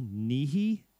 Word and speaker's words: Nighy: And the actor has got, I don't know how to pronounce his name, Bill Nighy, N-I-G-H-Y.